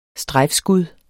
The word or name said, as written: STREJFSKUD